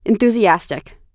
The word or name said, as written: enthusiastic